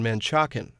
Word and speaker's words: Manchac: Marilyn McLeod is a third-generation Manchacan and president of the Manchaca Onion Creek Historical Association. She says Manchac is the correct pronunciation. – There is more support for the spelling M-A-N-C-H-A-C without the A on the end.